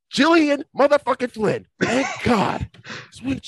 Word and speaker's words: Gillian: Can I just praise you for pronouncing her name right as well? Because I see so many people saying Gillian and, you know, all these weird pronunciations.